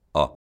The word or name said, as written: ó